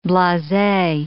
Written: blase

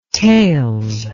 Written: tales

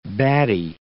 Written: baddy

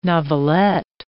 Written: novelette

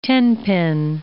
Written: tenpin

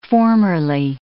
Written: formerly

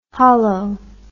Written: holo

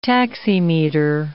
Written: taximeter

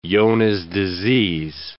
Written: Johne's disease

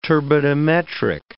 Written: turbidimetric

